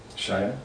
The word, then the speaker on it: Shia: Is it Shia? – Shia, yeah.